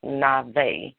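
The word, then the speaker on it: Navi (נביא): Prophetic comes from the word prophet, and the word prophet in the Bible deals with the Hebrew word is Navi, N-A-B-I-Y, but it's pronounced Navi.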